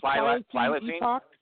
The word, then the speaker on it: Pliocene: Pliocene Epoch. I don't know if I'm saying it right. – Nice. Yes. – Pliocene Epoch.